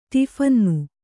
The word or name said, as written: ಟಿಫಿನ್ನು